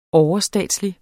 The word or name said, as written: overstatslig